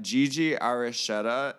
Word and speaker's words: Gigi Aricheta: Also, we're joined by Gigi Aricheta.